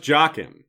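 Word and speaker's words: Jaqen: Yes, it's properly pronounced Jaken, right? As the show revealed to us, and the show isn't necessarily correct, but I think Jaken is probably correct.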